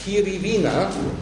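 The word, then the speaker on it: Kiriwina: indem er auf Kiri Wiener. Kiri schreibt man so wie Kiwi, aber mit R statt W. Und nach Kiri kommt Wiener, das schreibt man nicht so wie unsere Heimatstadt, sondern W-I-N-A.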